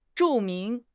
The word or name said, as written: zhu4 ming2